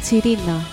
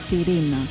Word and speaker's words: Τσιρίνα: Έτσι είναι το όνομα του γκρουπ που προφέρεται η Τσιρίνα. – Και όχι η Σιρίνα.